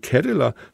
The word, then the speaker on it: Cadeler: Kadeler. C-A-D-E-L-E-R.